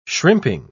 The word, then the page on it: shrimping: British English